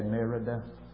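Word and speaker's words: Mérida: Well, we got to Merida, M-E-R-I-D-A, Merida, pronounced Merida, Mexico, on Wednesday in the evening, about 6 o'clock, I guess, that evening.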